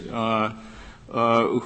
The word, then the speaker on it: Uyghurs: is it Uyghurs? Is that how it's pronounced? Uyghurs.